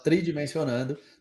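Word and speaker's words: 3Dimensionando: É, se escreve três Dimensionando mas se fala Isso. tridimensionando, tá no Spotify também, no Google Cast e todos esses lugares aí.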